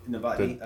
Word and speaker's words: Novotny: Tavotny? Tuva Tavotny? – Novotny? – Novotny. – Definitely starts with an N, not a T.